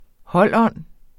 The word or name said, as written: holdånd